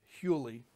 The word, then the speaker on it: huli: I think it's spelled H-Y-L-E. but in rabbinic Hebrew, it's pronounced Huli.